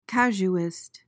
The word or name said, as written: casuist